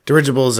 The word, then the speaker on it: dirigibles: Dirigibles are. – Said that right? – Yeah, you did say it right. It's dirigibles.